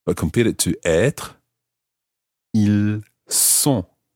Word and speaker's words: ils sont: So that time it's an S sound, a s. Ils sont. – Ils sont. – So in that situation, you're not pronouncing the S of the il, but you're pronouncing the S of the word sont, S-O-N-T.